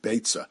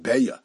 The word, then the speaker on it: Beitza: I pronounced it Beitza. We'll see that some pronounce it Beya.